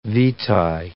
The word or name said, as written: vitae